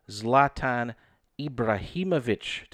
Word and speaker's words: Zlatan Ibrahimovic: Silver medalist from Team Holsey is Zlatan Ibrahimovic.